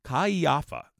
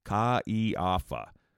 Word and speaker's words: Caiafa: Isn't his name hard to pronounce? Kaiafa. Say it with me. Kaiafa. Spelled C-A-I-A-F-A.